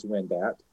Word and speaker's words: Wendat: Sadly, the last group of natives in the state were forced from their homes, and that would be the Wyandot. They also call themselves Wendat and various other pronunciations.